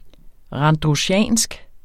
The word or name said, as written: RANDRUSIANSKE